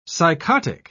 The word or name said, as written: psychotic